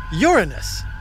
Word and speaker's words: Uranus: Of course, there is another way to pronounce Uranus. – There is? – Many NASA scientists and astronomers call it Uranus. – Uranus? Like urine? – I know, it's not much better.